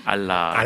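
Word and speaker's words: 알라 (ἀλλά): 알라. – 알라라고 했어요. 무엇을 알라가 아니라 발음이 알라예요.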